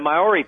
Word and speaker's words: Maori: Maori? – Yeah, the Maori people, which is, their First Nation people.